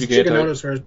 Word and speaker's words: Giganotosaurus: It was part of the early Indominus design with the Melusaurus looking strikingly similar to I'm saying Gigantosaurus. That's not right, is it? – It's Giganotosaurus, but don't worry.